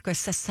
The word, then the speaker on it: Scorsese: Scorsese. – Mm. What? Am I saying it wrong? – No, you're saying it right.